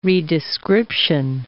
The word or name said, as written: redescription